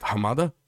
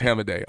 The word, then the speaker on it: Hamadeh: But I was like, is it Hamada? No, it's Hamadeh. I'm like, oh, okay, really? It's Hamadeh?